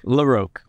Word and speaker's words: LaRoque: I said LaRoque. Is it LaRoque or LaRoque? – LaRoque. – Okay, I didn't say it right the first time.